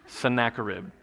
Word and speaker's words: Sennacherib: And King Sennacherib, I looked that up, I had to figure out how to pronounce that, King Sennacherib, and Jack, he checked me, so Sennacherib.